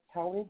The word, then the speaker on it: Toni: That's Tony Brown, T-O-N-I, last name Brown, Miss Brown Sugar, everything.